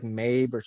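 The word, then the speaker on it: Mebh: It's like Mabe. It's M-E-B-H. – Yeah. – I think it's pronounced Mabe.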